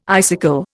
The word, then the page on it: icicle: Indian